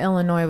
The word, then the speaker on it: Illinois: So I think that the first step of getting out of Illinois, I wanted to make sure I said it right. Getting out of Illinois was the first step,